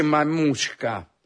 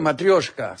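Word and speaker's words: matrioshka (матрёшка): Hola, no se dice mamushka. No existe esa palabra en ruso. Yo dije matrioshka.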